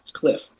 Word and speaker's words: Kliph: His first name is spelled K-L-I-P-H. That's right, Cliff.